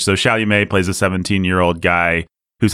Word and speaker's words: Chalamet: Chalamet, or however you say that guy's name. Chalamet? How do you say that guy's name? – Chalamet.